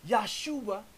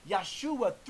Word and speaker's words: Yahshua: Now, Yahshua, three syllables, is the sacred divine feminine, because Christ is Alpha and Omega.